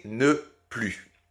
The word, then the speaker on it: ne plus: il faut toujours, toujours, toujours la prononcer ne plus donc euh, sans prononcer le S final.